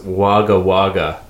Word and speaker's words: Wagga Wagga: It's pronounced Wagga Wagga. It's an Aboriginal word.